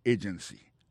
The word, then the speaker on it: agency: Pour mot agency, hein, accent tombé sous premier syllabe là. Agency. Deux autres syllabes qui sont derrière ou faible.